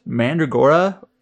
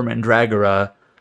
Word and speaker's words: Mandragora: I actually was wondering, is it pronounced Mandragora or Mandragora? Apparently it's either. I guess Mandragora is more common.